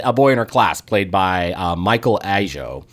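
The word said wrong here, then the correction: Ajao: or I should say Ajeo, pardon my pronunciation.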